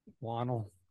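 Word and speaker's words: Whannell: Is that how you pronounce it? Wannell or Wannell? How do you pronounce it? – Wannell.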